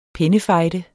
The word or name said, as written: pennefejde